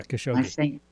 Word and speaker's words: Khashoggi: There's another thing that came out in Bob Woodward's book, and this has been the whole how do you pronounce his name? Khashoggi. – Yeah, Khashoggi. – Khashoggi.